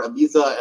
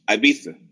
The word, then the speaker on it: Ibiza: A lot of the places I went to, one of them was Ibiza. Mm-hmm. Americans say Ibiza, but the Europeans call it Ibiza.